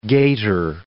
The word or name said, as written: gaiter